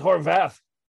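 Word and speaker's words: Horwath: I think it's Horvath. – It's Horvath? – Yeah. I think the W is a V. – Yeah, it's pronounced V, but yeah, it's Vath, not V.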